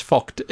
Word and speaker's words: Vogt: Actually, it's Vogt. It's Vogt. It's F long O C H T is how you pronounce it. I've learned this, but it's V-O-G-T. Vogt.